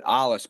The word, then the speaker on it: Alois: You know, we always said it Alois, but then I met people from Bavaria, and they told me it ought to have been Alois, and they were laughing at me.